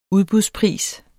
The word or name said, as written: udbudspris